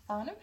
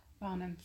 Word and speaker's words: Farnham: Um, we're in Farnham. How do we pronounce that? Farnham.